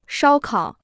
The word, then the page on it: 烧烤: shāo kǎo